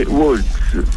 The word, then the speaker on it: Worx: L'avvisatore Waltz, si scrive W-O-R-X. – Cioè, come il lavoro è plurale. – No, o con la X o con la X.